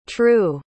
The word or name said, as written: trew